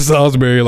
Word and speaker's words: Salisbury: Yeah, bro, it's dead ass Salisbury, like Salisbury steak.